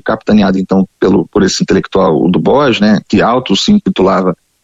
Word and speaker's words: Du Bois: Algumas pessoas falam W.E.B. Du Bois, ou falam com a pronúncia mais afrancesada, que é o Du Bois, William Edward Burrell Du Bois, ou Du Bois, que era a NAACP, National Association of Advanced of Colored People.